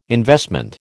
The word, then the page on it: investment: American English